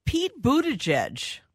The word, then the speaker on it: Buttigieg: Pete Buttigieg. And I believe I'm saying that right. I studied how to pronounce his name.